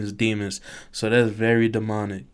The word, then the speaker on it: Demons: Demons. D-E-M-O-N-S.